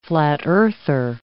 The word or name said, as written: flat-earther